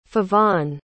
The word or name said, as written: feofan